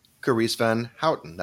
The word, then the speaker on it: Carice Van Houten: Uh, I'm Daniel Maté, and I'm here with my wonderful friend and co host, Carice Van Houten.